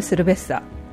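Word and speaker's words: cerveza: 例えば、母音で終わるセルベッサ、後ろから二音節目なのでベのところを強く読みます。それなのでセルベッサというふうになります。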